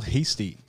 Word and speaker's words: Heastie: Dr. Samuel Heastie.